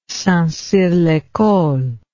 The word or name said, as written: St Cyr l'Ecole